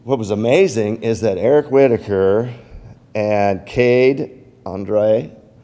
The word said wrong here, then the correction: Andrade: how you say it? Andrade.